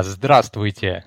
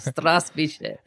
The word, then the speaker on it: Здравствуйте: Strasvice. How's that? Did I say it correctly? – Well, could you repeat one time again? – Okay, Strasvice. – Ah, I understood you. Здравствуйте. – Здравствуйте.